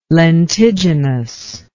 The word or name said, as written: lentiginous